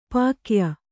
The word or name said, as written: ಪಕ್ಕೆಯ